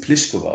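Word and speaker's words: Plíšková: Also, die wissen natürlich, wie sie ausgesprochen werden in Tschechien, aber die einen sagen, ich will so ausgesprochen werden, wie es international üblich ist, Pliskova, und die anderen sagen, ich möchte so gemacht haben wie in meiner Landessprache, also Pliskova.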